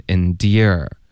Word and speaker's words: endear: Endure 再加儿化音，后面 endear 是一个爹加儿化音。